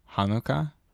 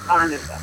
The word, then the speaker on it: Hanukkah: fun celebrating Hanukkah. – Yes, nicely pronounced. Tried more like you're hawking a loogie at the beginning. Hanukkah.